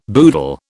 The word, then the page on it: boodle: British English